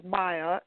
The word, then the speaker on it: Maija: Yeah, well, it's actually, my actual name is Myjah, M-A-I-J-A, but it's frequently mispronounced, which is why I go by the pseudonym sort of Myjah as well.